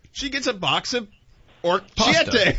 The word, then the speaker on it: orecchiette: Orecchiette. – Orecchiette.